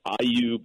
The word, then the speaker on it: Ayu: This is from Ayu Bakehouse. That's A Y U, pronounced like Bayou.